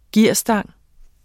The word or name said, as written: gearstang